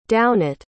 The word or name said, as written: downett